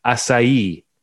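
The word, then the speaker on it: açaí: Recently I've been making smoothies with, and I only recently learned how to pronounce this word because I work with some Brazilians now. Acai. It's A-C-A-I.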